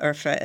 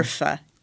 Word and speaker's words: Urfa: Urfa is a type of Middle Eastern kind of mild pepper. – How do you say that again? I'm sorry. – Urfa. Urfa. U-R-F-A.